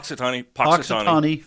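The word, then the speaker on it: Punxsutawney: Is it Pox? How do you pronounce that? Am I pronouncing that right? – Poxitani Phil.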